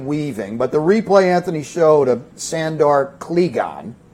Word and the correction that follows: Clegane: And so Your oh, I have Sandor Clegane. I think the A is long.